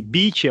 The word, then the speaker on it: beach: E beach é uma palavra muito difícil da gente pronunciar, né? É uma palavra, quer dizer, palavra fácil de pronunciar, mas ela tem uma nuance ali, né? Esse fonema que ela não é natural pro brasileiro. Então a gente fala beach e bitch quase do mesmo jeito, né? – Sim, sim, sim.